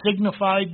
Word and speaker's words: signified: It says signified there. It should be pronounced signified, because John is going to be given the book in signs and symbols.